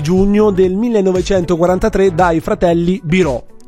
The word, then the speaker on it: Biro: Cioè, in realtà Biro, scusa, non okay, Biro. – Perché infatti l'accento è chiuso.